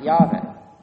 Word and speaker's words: Yahweh: That's because in the Hebrew, it's the actual name of God, which was probably pronounced something like Yahweh.